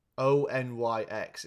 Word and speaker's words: Onyx: Um, the tang- the song is called Tango by the Oinks Collective. I'm saying that wrong. O N Y X. It's like a fucking metal or something, isn't it? Onyx. Onyx. Okay.